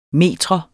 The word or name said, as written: metrum